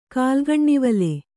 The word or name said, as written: ಕಾಲ್ಗಣ್ಣಿವಲೆ